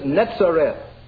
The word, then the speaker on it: Netzareth: That word is netzer, and the word and the name Nazareth in its Hebrew pronunciation is Netzareth.